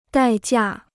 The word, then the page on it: 代价: dài jià